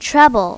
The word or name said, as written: trouble